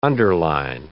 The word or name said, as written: underline